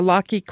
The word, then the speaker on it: Laki: This was the volcanic eruption in Iceland at Laki. Am I pronouncing that right? – Yeah, the Laki craters.